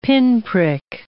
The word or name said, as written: pinprick